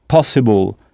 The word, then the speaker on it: possible: Et attention, on n'insiste pas sur le i comme en français. En français, on dit possible. Mais sur la syllabe qui précède cette terminaison. Ça donne able, possible, possible. C'est comme si on frappait fort sur cette syllabe.